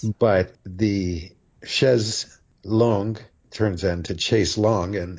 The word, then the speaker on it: chaise longue: In French, it would be chaise longue. Chaise. A-I is pronounced eh. Chaise longue.